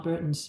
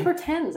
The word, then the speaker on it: Bertens: Yeah, how can you say Mladenovic and not Burton's? But anyway, because I think it's Bertens. I think it's Dutch. I think it's a Dutch pronunciation.